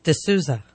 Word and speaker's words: D'Souza: D'Souza. – D'Souza. Dinesh. – Dinesh. – Dinesh. – Can you say it? Dinesh. – So like that's like the Kanish. He's the Kanish D'Souza.